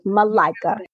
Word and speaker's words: Malika: I say it's Malika, as in I like a Malika. So yes, Malika.